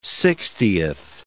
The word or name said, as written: sixtieth